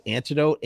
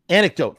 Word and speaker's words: anecdote: Is it antidote? – Anecdote.